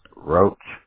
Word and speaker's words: Roach: Roach, R-O-A-C-H. R-O-A-C-H, just like the bug.